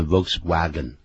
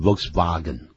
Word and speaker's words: Volkswagen: 其实不是念的 Volkswagen，是就是上面那个车子啊，那个型号是 VW 这两个英文字母，有没有？它应该念成 Volkswagen。